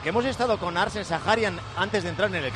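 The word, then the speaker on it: Zakharyan: Por cierto, nos ha dicho que se pronuncia, ya lo dejamos bautizado de aquí para siempre, Sajarian. Así que a partir de ahora, Arsène Sajarian.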